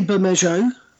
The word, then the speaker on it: Bermejo: but I'm going to say Louis Bermejo. Is that how you... – I say Bermejo.